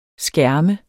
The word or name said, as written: skærme